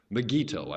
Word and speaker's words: Megiddo: Megiddo, I should pronounce it.